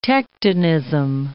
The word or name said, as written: tectonism